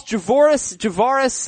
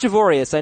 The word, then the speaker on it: Javorius: True or false, Javoris, yeah, it's not Javorius. I know that. I actually looked up some pronunciation guides. It's said Javoris Allen, a must-start running back going forward.